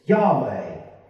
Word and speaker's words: Yahweh: It's the English translation of a Hebrew sound consisting of four letters. YHWH. And our best guess at its pronunciation is Yahweh.